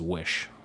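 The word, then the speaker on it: Oysi: Apparently that's how it's pronounced, is Wish, which doesn't make any sense to me because it's O-Y-S-I.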